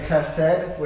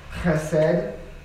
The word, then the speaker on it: chesed: Everyone say chesed with the throat. Chesed. Okay, no one's going to say it. On the count of three. One, two, three. Chesed. Right. It's a Hebrew word. You know you're saying it right in Hebrew if you're like coughing something up out of your lungs.